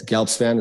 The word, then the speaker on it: Gelbspan: It's Gelbspan.